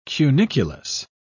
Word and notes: cuniculus: British English

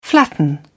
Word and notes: flatten: British English

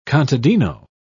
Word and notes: contadino: British English

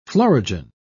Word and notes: florigen: British English

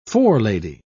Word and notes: forelady: British English

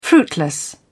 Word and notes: fruitless: British English

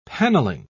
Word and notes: panelling: British English

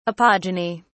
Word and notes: apogeny: British English

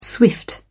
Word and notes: swift: British English

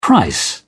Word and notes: price: British English

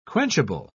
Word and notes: quenchable: British English

